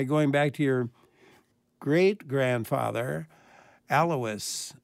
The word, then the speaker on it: Alois: You know, we always said it Alois, but then I met people from Bavaria, and they told me it ought to have been Alois, and they were laughing at me.